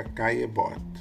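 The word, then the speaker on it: Caillebotte: A gente não escreve, a gente não fala Caelebote, a gente tem que suprimir esses dois L's, então fica Caebot e é assim que a banda toca no francês.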